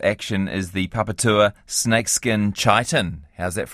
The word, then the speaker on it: chiton: It is the papatua snakeskin chiton. – Yeah, so, that's what I said. That H is silent, everyone. Don't make that mistake.